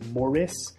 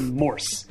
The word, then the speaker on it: Morse: Yeah, and just to clarify one thing that I've been meaning to do for a few weeks, if you guys hear Robbie pronounce my name as Morris, like Zach Morris on Saved by the Bell, it's actually Morse, like Morse code. So if you're looking for me on Twitter, it's Ben J., M-O-R-S-E.